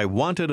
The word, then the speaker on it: wanted: I wanted a beer. Wanted. – Si è ricordato che la ED in questo caso si pronuncia come una sillaba separata? – Now read number 8.